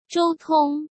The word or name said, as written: Zhou Tong